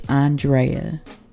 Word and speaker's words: Andrea: I am your host, Andrea, or Drea if you continue to butcher the A N of my goddamn name just it's still fucking y'all up, huh? It's still it's still fucking y'all up in these streets. It's Andrea. That's it or Drea, but I prefer Andrea.